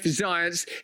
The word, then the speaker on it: Zients: Check out once more Jeff Zients. His name rhymes with science, and he's yearning for a winter of death.